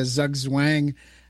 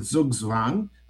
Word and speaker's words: Zugzwang: Well, I pronounce it Zugzwang. Okay. So, anyone who pronounces German much better than you and me can pronounce it the way they wish.